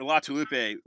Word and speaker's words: Latulipe: My name is actually Steve Latulip, it's pronounced. It's easier than it sounds.